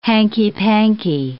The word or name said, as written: hanky-panky